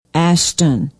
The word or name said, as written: ashton